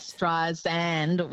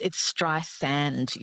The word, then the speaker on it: Streisand: It's actually Streisand, like sand on the beach. – I'm not pronouncing it. Oh, my God. I'm under the desk. – I know. Look, everyone says Streisand with a Z, but it's Streisand. Yes, she's very specific about that.